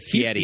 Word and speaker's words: Fieri: Well, I think, first of all, I'm pretty sure it's Fieri, like it's spelled F-I-E-R-I, that's how it's spelled. – But it's pronounced Fieri, is that right? – I think he pronounced it Fieri. – He does pronounce it Fieri, but I've always been confused by that because then I see it in print, and it.